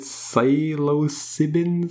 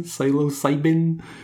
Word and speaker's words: psilocybin: He was inspired by a substance called, and I can't pronounce this, is it psilocybin? Psilocybin? – Yeah.